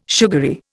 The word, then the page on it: sugary: Indian